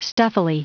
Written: stuffily

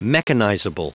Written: mechanizable

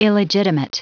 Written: illegitimate

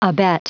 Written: abet

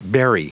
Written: berry

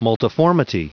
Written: multiformity

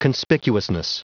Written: conspicuousness

